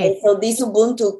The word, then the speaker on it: Ubuntu: Ubuntu is spelled U-B-U-N-T-U.